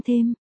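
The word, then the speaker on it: Thames: Một, sông Thêm, phát âm như là sông Thêm, là con sông ở phía nam nước Anh, nó là con sông quan trọng nhất ở Anh.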